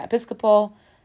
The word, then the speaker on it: Episcopal: Did I pronounce that right? Episcopal. Yeah. Episcopalian. Yeah. Episcopal.